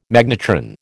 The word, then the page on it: magnetron: American English